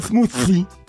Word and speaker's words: smoothie: Il aimerait une recette de smoothie.